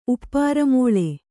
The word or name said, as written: ಉಪ್ಪಾರಮೋಳೆ